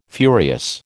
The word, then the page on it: furious: American English